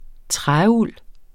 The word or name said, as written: træuld